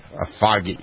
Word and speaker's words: Afaghi: Asagi with an F. – With an F? – Yeah, A-F-A-G-H-I. – Gotcha. Asagi.